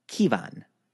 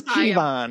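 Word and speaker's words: Ciabhán: It's spelled C-I-A-B-H-A-N, or pronounced Kevan.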